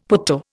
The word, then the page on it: putto: Indian